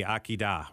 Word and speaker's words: Akedah: How do you say it? Akedah? – The Akedah, right.